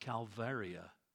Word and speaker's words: calvaria: But in Latin, skull is pronounced Calvaria.